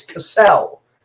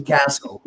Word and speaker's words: Castle: In my entire year in professional wrestling, motherfuckers from East Coast to West Coast have thought it was Cassell, as opposed to Castle, which is its actual pronunciation.